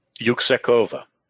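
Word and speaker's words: Yüksekova: Yuksekova.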